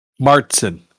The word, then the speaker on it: Märzen: It's a Marzen Lager, or maybe a Märzen Lager. If those two dots are doing anything. – Martzen is the pronunciation. I'm led to believe.